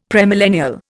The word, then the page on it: premillennial: Indian